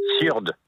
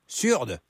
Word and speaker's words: Sjoerd: Parce que ce monsieur, ah oui, rien que son prénom, c'est S-J-O-E-R-D. Et il faudra... Sjord. Oui, comment vous dites ? – Sjord. – Sjord, ça se prononce oui. Sjord. Ah oui. Vous êtes sûr ? Parce que non, franchement, S-J-O-E-R-D. – Je suis sûr.